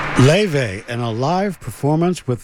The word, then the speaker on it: Laufey: And if you look for any of her music, Leve, you have to understand that the spelling is totally different from how she pronounces it. If you're looking for it, Leve is spelled L-A-U-F-E-Y.